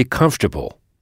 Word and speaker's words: comfortable: Vous voyez que c'est pratiquement le même mot qu'en français, mais la prononciation est très différente. Comfortable. Pas de son on, puisque ce son n'existe pas en anglais. Et ici, l'ensemble COM se prononce comme. Et notez que OR est à peine audible, comfortable, et que c'est la première syllabe qu'il faut frapper.